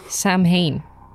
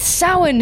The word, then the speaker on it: Samhain: An American show and they mention Samhain in it, and they pronounce it Samhain. – Samhain. – I was watching Halloween 2. – Is that Halloween 3? – 2. – 2. – Oh my god. Bothers the life out of me. And then there's like Trick or Treat as well, where I don't mind this as much because the main character's name is Sam, but his full name is Samhain, and I'm like, it's Samhain. His name would not be pronounced that.